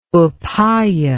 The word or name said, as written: upaya